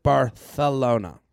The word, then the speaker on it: Barcelona: Jared Shelby and a guest sending you the feathers from Barcelona. They pronounce it Barthelona.